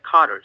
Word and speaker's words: Carter: are you saying? – No, it's J-O-H-N John, and my last name is Carter, C-A-R-T-E-R.